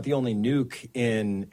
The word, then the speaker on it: Nuuk: That is how you say it, not Nuuk. It is Nuuk.